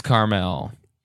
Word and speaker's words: Carmel: Like, I feel like any road trip I was going to plan for this episode would have ended up with me at Caramel by the Sea. – Wait, Carmel. – Carmel. – Right? – I don't know. – Pronouncers, you know. – It is Carmel.